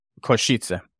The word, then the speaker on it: Košice: Košice, is that how you say it? Košice.